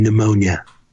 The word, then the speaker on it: pneumonia: Did you know that the P is silent in pneumonia?